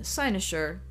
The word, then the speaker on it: cynosure: kinosure. Ultimately, I decided on cynosure, side eyes be damned.